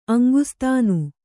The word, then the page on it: ಅಂಗಸಾಧನೆ: kannada